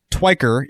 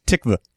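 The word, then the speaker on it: Tykwer: In the chat, they're pointing out that, first of all, the guy's name is, okay, it's not Twiker, it's Tyquer, and it's actually the most close pronunciation that I'm getting out of the chat from Swedish people is Tikva.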